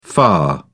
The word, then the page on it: lah: British English